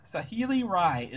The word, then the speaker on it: Saheeli Rai: Anyway, but her name, and thankfully Wizards gave us a pronunciation guide already on this one. Is Sa- Saheeli Rai. Saheeli Rai is the new planeswalker.